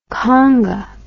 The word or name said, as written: kanga